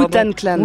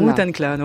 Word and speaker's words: Wu-Tang Clan: Wu-Tang Clan. – Wu-Tang Clan, autant pour moi, allez.